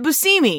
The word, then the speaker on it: Buscemi: He pronounces it Buscemi.